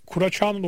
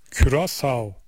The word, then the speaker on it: Curaçao: Amsterdam'dan Serkan, Kura Çağ'ın okunuşu Türkçe'de Curaçao'dur diyor. Uf, çok farklı ya. Curaçao. Böyle okunduğunda herkes anlar diyor. Çünkü bu da internasyonel okunuşudur demiş.